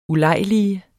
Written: ULEJLIGEDES

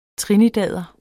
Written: trinidader

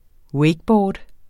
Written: wakeboard

